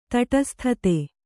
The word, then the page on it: ತಟಸ್ಥತೆ: taṭasthate